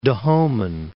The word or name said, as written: Dahoman